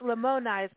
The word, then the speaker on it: Lamoni: And by the way, so it's Lamoni. So again, I'm wrong in the pronouncing. I was like Lamoni is the town.